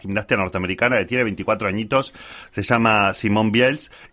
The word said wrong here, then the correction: Biles: Biles. Biles.